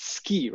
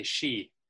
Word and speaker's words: Ski: So hailing from, I think you pronounce it Ski, right? Ski, Norway. Is that how you pronounce your hometown? – Yeah, almost. It's spelled S-K-I, but we say she.